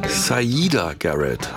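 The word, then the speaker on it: Siedah Garrett: Dennis Edwards und Sierra Garrett. Moment, stopp. Zurückgespult. Saida Garrett habe ich neulich gelernt. Ich habe es auch immer falsch ausgesprochen.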